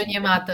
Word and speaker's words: Onyemata: Tem um defensive tackle que eu acho ele também bem subestimado, que é o Onyemata. Eu acho que eu pronunciei o nome dele certo. – David Onyemata, aham.